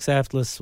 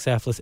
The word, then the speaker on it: Saftlas: LinkedIn, Instagram, Facebook, Yitzhak Saftless, YITZCHOK SAFTLAS. Probably any way you spell it will be fine. I'm the only Yitzhak Saftless in America, as far as I know.